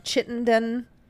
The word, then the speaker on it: Chittenden: The Eddie House in Chittenden. Yep. That's how you say it.